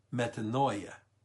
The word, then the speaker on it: metanoia: And the Greek word for repentance or to repent is a verb in Greek called metanoia. M-E-T-A-N-O-I-A. Metanoia. Oh, see, that's what I get for not having it written down. M-E-T-O-N-O-I-A. I think I said it right.